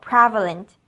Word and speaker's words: prevalent: 今天的这个句子首先要注意 prevalent，它有三个音节，重音是 pre 这个地方，因此念作 prevalent。